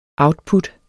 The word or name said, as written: output